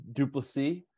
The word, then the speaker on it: Duplessis: And then it seems like this Vinny, how'd you pronounce his last name? – Duplessis. – That sounds right. He's from Quebec. So yeah, you probably have to throw that French pronunciation in there.